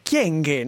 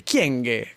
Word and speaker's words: Kyenge: Tra l'altro, questa cosa, ma perché fate apposta a pronunciarla tutti male? Chienghe, non è difficile, chienghe, così, non...